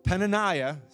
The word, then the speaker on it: Peninnah: And then Peninnah, sorry I said it wrong the first time, is the pearl or precious stone.